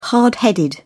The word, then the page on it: hard headed: British English